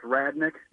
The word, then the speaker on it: Radnich: It's pronounced Radnick, spelled R-A-D-N-I-C-H.